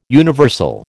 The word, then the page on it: universal: American English